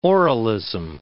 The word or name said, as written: oralism